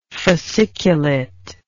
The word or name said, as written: fasciculy